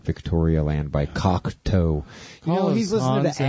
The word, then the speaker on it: Cocteau: No, it's spelled C-O-C-T-E-A-U, Cocteau Twins.